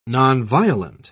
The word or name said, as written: nonviolent